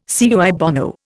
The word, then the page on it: cui bono: Indian